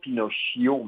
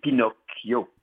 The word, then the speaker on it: Pinocchio: Exemple, Pinocchio, on ne dit pas Pinocchio, même si, comme lecteur francophone, on lit bien Pinocchio, oui. – Mais c'est Pinocchio. – Pinocchio, le CH comme un K.